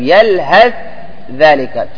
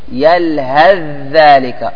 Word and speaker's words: يلهث ذلك: طيب يلهث ذلك، تقول يلهث ذلك. – يلهث ذلك. – يلهث ذلك. – يلهث ذلك. – لا تنطق بشي ثاء. يلهث ذلك.